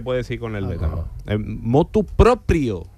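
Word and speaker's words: motu proprio: No se dice de motu propio, se dice de motu propio.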